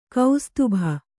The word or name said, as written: ಕುಸ್ತುಭ